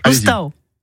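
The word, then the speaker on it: l'oustau: La maison en provençal, ça se dit Anne. Vous le savez puisque vous l'avez prononcé hier. – Loustau. – C'est Loustau.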